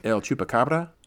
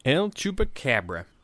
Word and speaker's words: El Chupacabra: El Chupacabra? – El Chupacabra.